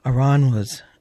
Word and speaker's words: Aranjuez: Aranjuez.